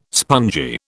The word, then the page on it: spongy: British English